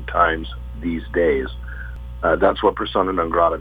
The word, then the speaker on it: Persona Non Grata: No, Persona Non Grata. That's how you pronounce it.